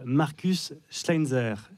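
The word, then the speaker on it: Markus Schleinzer: c'est « Rose » de Marcus Schleinzer. J'ai bien prononcé, je suis toujours un peu inquiet. – Quand... Non, mais c'est parfait.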